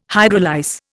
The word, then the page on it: hydrolyse: Indian